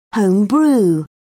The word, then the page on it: home brew: British English